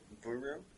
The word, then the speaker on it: Buru: Yes, they are not able to pronounce our name properly. – Ah, okay. So, Buru? – B-U-R-U. Kind of hard to do bilabial when you have no lips.